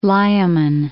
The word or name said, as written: Layamon